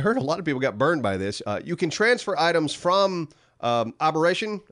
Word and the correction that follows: Aberration: How do you say it? – Aberration.